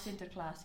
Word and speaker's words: Sinterklaas: is Sinterklaas. – Yes, I was about to say that. – Yeah. I don't know if I'm saying... You probably know how to say it. – Sinterklaas. – Sinterklaas, yeah, because everyone